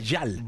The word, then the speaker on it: Gyal: Oye, a ver, fíjate cómo Bad Gyal, ¿verdad? Se pronuncia Yal.